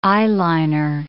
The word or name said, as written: eyeliner